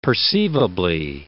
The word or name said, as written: perceivably